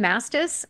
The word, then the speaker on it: Mastis: It's Lindsay with an E, L-I-N-D-S-E-Y. My last name, Mastis, it's phonetic. It's M-A-S-T-I-S.